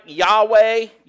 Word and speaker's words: Yahweh: But the Hebrew word would be pronounced something like Yahweh.